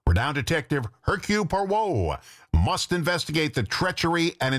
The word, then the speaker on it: Hercule Poirot: And yes, his name is pronounced Hercule Poirot.